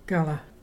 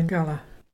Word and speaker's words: garla: Our local Mulgana word today is Gala. It's fire. And to spell that is G-A-R-L-A. Can you say Gala?